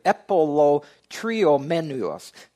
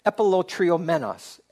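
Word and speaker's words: epilotriomenos (ἀπηλλοτριωμένους): It's got seven, this is the Greek word, epilotriomenos. Epilotriomenos. Epilotriomenos. Seven syllables.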